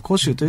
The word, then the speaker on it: 湖州: はい、その同じ広州の中にですね、まあ、最古の見えるほとりのホテルの中に、 1 店舗、それから上海のに 1 店舗ですね、それから、寧波ですね、寧波というところに 2 店舗、ね、それから湖州、湖の州と書いて湖州というところに 1 店舗で、うん、今 6 店舗でございます。